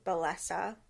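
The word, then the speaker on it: Bellesa: I know that personally, one of the ethical sites that I have found is, it's like Bellessa, I think is probably how most people would pronounce it. – But could it's you spell Bellessa. It? – It's B-E-L-L-E-S-A.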